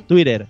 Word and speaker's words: Twitter: ¿Cómo lo escribí? Twitter. T-U-I-R-E-R. Twitter.